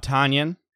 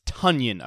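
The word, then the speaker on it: Tonyan: Remember everybody's favorite new tight end, Big Bob Tanyan? No, it's Tunyon, apparently. Matt Schneidman over at The Athletic broke the news. Packers PR, he says, has alerted us that Robert's last name is pronounced Tunyon, as in rhyming with Funyun.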